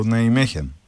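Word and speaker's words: Nijmegen: Ναι, βέβαια εγώ θα ήθελα να πω ότι θεωρώ ότι είναι καλή ομάδα η ΑΕΜΕΧΕΝ. Ή Ναϊμέχεν, όπως ο καθένας θέλει να την προφέρει. – Ναι, μου είπαν ότι είναι Ολλανδικό Ναϊμέχεν.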